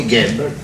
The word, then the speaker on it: Gable: Il y a écrit Gable. On doit prononcer Gable. Ah. Moi, je vois écrit Ga, je dis Ga. Depuis l'âge de 6 ans, j'ai toujours appris ça. C'est pas à plus de 30 ans que je vais changer de prononciation.